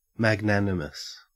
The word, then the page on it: magnanimous: Australian English